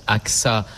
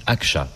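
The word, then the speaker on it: Ağca: Et qui raconte la vie d'Ali Argay. – Alors, ça se prononce Aksa en français, mais en turc, Aksa, puisqu'il était turc.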